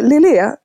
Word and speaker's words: Lillet: Ja, och vi var osäkra på uttalet. – Där kom det, Lillet.